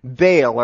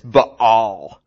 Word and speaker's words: Baal: The next one is a guy named Baal, or actually the correct pronunciation is Baal.